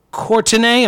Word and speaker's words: Courtenay: Downtown Courtenay, am I saying that right, Canadian listeners? – I think you are.